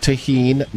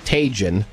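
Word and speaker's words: Tajín: It's pronounced Tajin, not Tajin.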